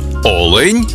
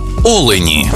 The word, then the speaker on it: олень: однині і в множині наголос падає на перший склад – олень та олені.